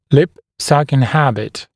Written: lip sucking habit

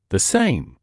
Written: the same

